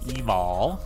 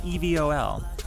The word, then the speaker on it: Evol: I would say Evol, but it's like evil, but with an O.